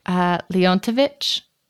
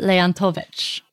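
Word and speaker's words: Leontovych: Leontovich.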